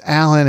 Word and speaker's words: Alan: You know, people spell it wrong all the time, but it's Alan as in Steve Allen.